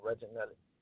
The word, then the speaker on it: Reginelli: All you got to do is just type in my name, Reginelli, and it's spelled R-E-G-I-N-E-L-L-I.